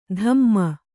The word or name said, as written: ಧಮ್ಮ